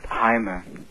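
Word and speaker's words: Jaime: Ach, ich muss dich korrigieren, das heißt Heime. – Wie heißt das? – Heime? Ja, richtig, das wird praktisch wie ein H ausgesprochen. Ja. Das ist ein spanischer Name.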